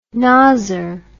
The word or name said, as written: nozzer